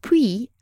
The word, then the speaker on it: Puy: Puy, as in his name, is spelled P U Y, but it sounds exactly like puits, P U I T, which is French for well, as in water well.